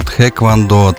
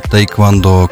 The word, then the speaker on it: тхэквондо: Ну, вообще можно называть по-разному. Тхэквондо, тейквондо. Кто-то называется просто тэквандо.